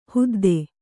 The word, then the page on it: ಹೊದ್ದು: Kannada